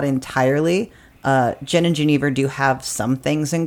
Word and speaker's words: Genever: Well, the spirit of interest today is Genever. That's how I was taught to pronounce it. I feel like that's sort of an English butchering of it. It's a Scandinavian spirit. It's from the Netherlands and Belgium. The pronunciation is probably more appropriately Genever.